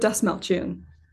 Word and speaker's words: Dastmalchian: David Dastmalchian.